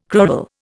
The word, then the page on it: crural: Indian